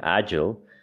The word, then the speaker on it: agile: estoy como estaba, checando este tema de la pronunciación y pues se supone, estaba, investigando, estaba leyendo y se supone que la pronunciación correcta es como ayo. Eh, como así como, como si no existiera algo entre la G y la L, agile.